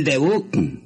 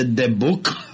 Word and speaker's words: debut: Pero la verdad es que el sábado es el debut del circo. – Y además no se dice debut. – ¿Cómo se dice? – Se dice debut. Ah, con T.